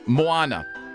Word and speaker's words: Moana: A woman in Georgia recently asked Dairy Queen to make a birthday cake for her 25-year-old daughter, who loves the Disney movie Moana. I hope I said that right. – Moana. – I should. Yes, Moana.